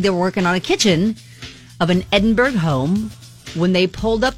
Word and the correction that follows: Edinburgh: Now, I need to stop you real quick because you're pronouncing it like you're in Indiana. It's Edinburgh.